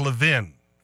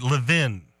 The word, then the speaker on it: Levin: Mark Levin. You pronounce it Levin.